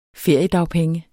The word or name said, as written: feriedagpenge